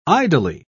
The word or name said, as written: idly